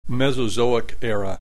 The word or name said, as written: Mesozoic Era